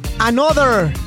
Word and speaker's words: ANOTR: Another, pero se escriben como A-N-O-T-R.